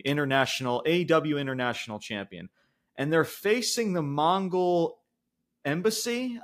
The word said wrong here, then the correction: Mogul Embassy: I want to say that's how you pronounce the faction. – The Mogul Embassy. – Mogul. Yeah, it's something along those lines, guys.